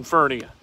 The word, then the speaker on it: Furnia: It rhymes with hernia. It is not Furnia. It is Furnia.